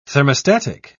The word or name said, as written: thermostatic